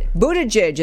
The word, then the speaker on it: Buttigieg: Voters should not reward Buttigieg is how you say his name.